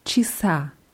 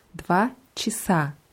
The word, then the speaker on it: часа: Dwa Rasa hatten wir mit A am Ende und genauso bekommt das Wort Chas auch ein A am Ende, nur die Betonung ist anders. Dwa Chisa. – Dwa Chisa. – Ja, das erste A wird hier eher wie ein I ausgesprochen und das letzte A betont. Dva часа.